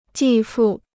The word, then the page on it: 继父: jì fù